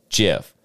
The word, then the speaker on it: GIF: It is a soft G pronounced GIF. End of story."